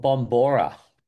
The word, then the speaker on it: Bombora: So you're talking about Bambara. Is that what you're talking about? Did I pronounce it right? – Yeah, we call it. It's called Bombora. – Bambora, sorry.